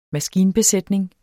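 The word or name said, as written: MASKINBESÆTNINGEN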